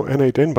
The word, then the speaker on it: n8n: And I think the official way to pronounce it is now n8n, but it was Node Mation not too long ago.